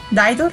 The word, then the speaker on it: Dido: Y eso nos dice por acá Dido, o Daido, no, Daido, realmente se pronuncia, con esta canción tan bonita que se llama Thank You.